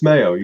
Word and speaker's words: Maio: Did I get your name right? Is it Maio? – It's Maio. You got it.